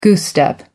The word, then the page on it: goose step: American English